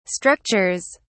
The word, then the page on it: structures: British English